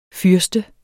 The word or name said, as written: fyrste